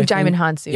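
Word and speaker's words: Djimon Hounsou: Also, I don't know how to pronounce his name. Jimon Hounsou. – Oh, Jimon Hounsou. – Yes.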